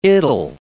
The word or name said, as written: it'll